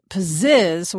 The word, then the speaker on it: Pzizz: I use an app called Pizzizz, which is spelled P-Z-I-Z-Z, which was originally created to help cure insomnia.